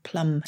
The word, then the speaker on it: plumb: So plum, in this sense, we kept more or less the pronunciation of the B. I guess it's still plum. – No, it's still oh, it's plum as it? in plumb line with a B on the end, not exactly.